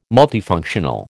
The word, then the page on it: multifunctional: American English